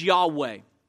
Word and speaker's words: Yahweh: Sometimes pronounced as Yahweh.